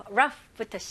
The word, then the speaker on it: rough: GH는 F로 발음하셔야 되겠죠? 여기서 O, U 너무 헷갈려 하지 마시고요. 그냥 U 발음, rough. Rough, rough, very good. F 발음을 명확히 해주셔야 되고요. R도 힘을 주시면서, okay?